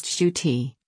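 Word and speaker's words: Ncuti: Nkuti dazzled us, seized hold of the Doctor and owned those TARDIS keys in seconds. Gatwa, whose first name is pronounced Shuti,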